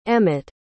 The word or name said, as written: emmett